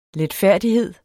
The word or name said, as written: letfærdighed